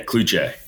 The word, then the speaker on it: Cloutier: Yeah, Cloutier.